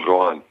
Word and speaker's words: Rouen: nach Rouen, wenn ich das richtig ausspreche. – Genau, genau, Rouen.